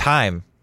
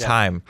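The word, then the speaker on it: Chaim: Excuse me, actually, it's Heim. We were saying it wrong. It's Heim.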